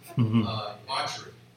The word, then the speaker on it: debauchery: I mispronounce that word all the time. Boshery, boshery, boshery, debauchery.